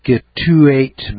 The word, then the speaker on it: Scituate: A gentleman farmer in Scituate Village, it's spelled S-C-I-T-U-A-T-E, Scituate Village, Rhode Island, sent a check to pay down his credit card bill.